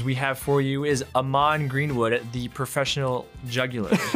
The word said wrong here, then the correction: juggler: The jugular? – Sorry, juggler. Juggler.